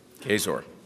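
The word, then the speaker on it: Kaczor: She has co-authored Life Issues, Medical Choices, Questions and Answers for Catholics with Chris Caxor, is that how you say it? – Kazor, thank you.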